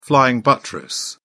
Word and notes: flying buttress: British English

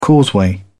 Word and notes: causeway: British English

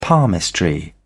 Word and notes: palmistry: British English

